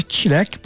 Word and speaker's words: çilek: Jilek in Turkey is pronounced a bit differently, çilek, but it means a strawberry, to be specific.